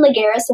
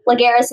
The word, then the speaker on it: Lagares: Follow me at Madison Lagares Official. So that's my Instagram name. Lagares is spelled L-A-G-A-R-E-S.